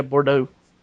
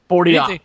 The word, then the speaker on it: Bordeaux: You butcher that name to hell. – Bordeaux. Yeah, anything with an X I have trouble with. – Yeah, just it's French. Like, that makes the O sound.